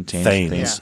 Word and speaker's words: Thames: Thames. – Thames.